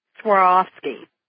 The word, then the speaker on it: Swarovski: Swarovski. And the way to say it, excuse me, is like you're swearing, but in past tense, swore, on and off, and you're skiing, S-K-I, swore, off, ski, Swarovski.